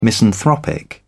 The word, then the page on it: misanthropic: British English